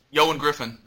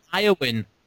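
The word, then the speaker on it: Ioan Gruffudd: Eoin Griffin. That's his name. I know. – Thank you. Very good. Well done. Some people call him Eowyn.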